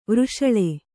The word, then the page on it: ವೃಷಲ: vřshala